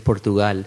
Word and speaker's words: Portugal: Because like I heard one interview where they called you Portugal. Portugal. Is that right? – Actually, it's Portugal.